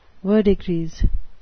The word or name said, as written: verdigris